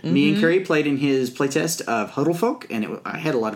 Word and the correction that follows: Huldefolk: Is Huldefolk. – I think you may be closer to his pronunciation.